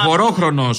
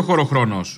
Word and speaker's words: χωρόχρονο: Ο χορόχρονο, όχι ο χωροχρόνο.